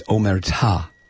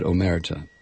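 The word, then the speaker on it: omertà: It's omerta. – Oh. – Yeah, sorry. That's all right. The accent is on the last syllable.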